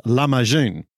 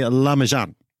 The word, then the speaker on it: lahmacun: The Turkish version of this word is pronounced lahmacun, I understand. June at the end of it. I'm a Kiwi, so we tend to say lahmacun, but I think we all know what we mean.